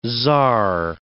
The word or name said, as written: tsar